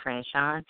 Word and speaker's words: Franchon: My name is Suite, spelled like the hotel suite, S U I T E, Franchon, F R A N C H O N.